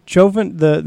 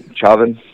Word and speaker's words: Chauvin: how do you pronounce his last name? Chofin? Chauvin, Chauvin.